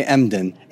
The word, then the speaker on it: Emden: Rabbi Emkin. Did I mispronounce that? – Rabbi Emden.